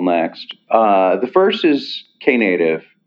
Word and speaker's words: Knative: And we just saw at Google Next that I believe Knative is how it's pronounced.